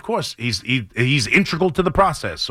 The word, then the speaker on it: integral: And I looked it up, and it's integral. I believe that's the pronunciation for it.